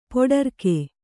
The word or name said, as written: ಪೊಡರ್ಕೆ